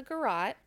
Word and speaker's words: garrote: Sonia's cause of death was strangulation with a garrote, as well as the stab wound she received. – With a gyat? – Garrote? Did I say gyat? No.